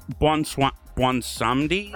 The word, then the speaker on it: Bwonsamdi: It's yeah, so Bwonsamdi? – Right.